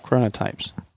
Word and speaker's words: chronotypes: C-H-R-O. – Yeah, it sounds like you're saying corona. – No, I'm not saying corona. – I did not pick up that. I thought you pronounced it really, really well. – Chronotypes.